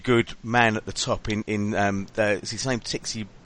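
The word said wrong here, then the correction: Txiki: Begistaran, I think you pronounce it or something like that, yeah, okay. – Cheeky Begistaran. – Cheeky. – Thank you very much.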